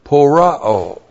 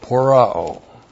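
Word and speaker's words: pōroō: So under etymology, first of all, the verb that we have in our verse, porao. P long O, R O long O, porao.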